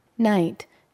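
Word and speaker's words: knight: Night, night. – 기사의 자귀를 수여하다. – K는 읽지 않죠. 묵음이 돼버리죠. 자, night. 밤이라고 하면 안 됩니다. K자가 있을 때는 기사라는 뜻이 되겠습니다.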